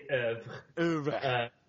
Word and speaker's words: oeuvre: Just for the record, we say oeuvre.